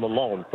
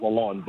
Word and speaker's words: Lalonde: And I was told to call him Lalonde from the Detroit guys, not Lalonde, but I hope I'm saying it right.